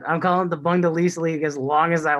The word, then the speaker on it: Bundesliga: Now that your mispronunciation has gone into two weeks, I just allowed it to happen last week, but it's the Bundesliga. It's the funniest thing when you said it a couple times last week.